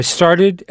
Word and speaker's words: started: Ici, la terminaison ed se prononce ed. Alors, j'ai commencé à 8 heures. – I started at 8 o'clock.